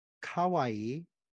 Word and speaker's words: kawaii: For example, those of you who speak Japanese, of obviously you'll learn kawaii, which means cute, right? And kowai with the O is like basically terrible, ugly, mean.